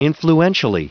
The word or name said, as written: influentially